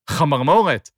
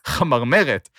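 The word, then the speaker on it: chamarmoret (חמרמורת): The term is chamarmoret, which is pronounced chamarmoret and not chamarmeret, as was pronounced on the podcast.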